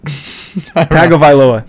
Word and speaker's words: Tagovailoa: Tua Tagovailoa. Yeah, I remember he separated his hip. – That's not how you say it. – Tagovailoa, that's it.